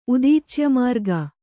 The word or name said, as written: ಉದೀಚ್ಯಮಾರ್ಗ